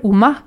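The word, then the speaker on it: Umá: Donc, euh, juste pour donner un peu une idée de à quoi pourrait ressembler le, style que fait Uma, Uma avec un accent à la fin d'ailleurs, donc il faut le prononcer à l'espagnol, Uma.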